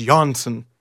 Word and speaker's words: Johnsson: And it's funny that we know there's a second S on there, so it's Johnsson. Like the second S means a Y. It means the soft J.